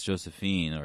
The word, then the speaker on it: Josephine: Yeah, this is a winding, twisty trail that led us here from Ayanis Josephine, with that soft J.